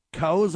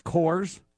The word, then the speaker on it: Kohrs: Emily Coase, I think is how you say her name, K-O-H-R-S, Coase, declined to name any of those who were referred for charges, including whether Trump was among them or not.